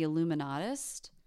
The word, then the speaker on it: Illuminaughti: I think she's called, I'll just tell you now because I'll forget, The Illuminatist. – Ooh. – The Illuminati. But it's spelled Illuminaughti.